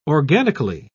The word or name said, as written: organically